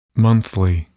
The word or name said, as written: monthly